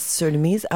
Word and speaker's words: Surinamese: You are Surinamese American. Am I pronouncing that right? – Yes, Surinamese. I like.